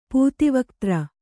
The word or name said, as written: ಪೂತಿವಕ್ತ್ರ